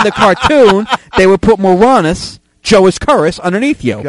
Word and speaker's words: Moronis: M-O-R-O-N-I-S. It's the Latin form for it's the Latin root of moron. It's Moranis.